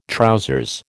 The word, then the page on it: trousers: American English